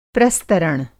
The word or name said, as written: प्रस्तरण